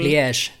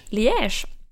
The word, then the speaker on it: Liège: Jag har ju hittat en, en studie från det belgiska universitetet Liège kanske det uttalas, eh, som har studerat vittnesmål... – Liège. – Liège.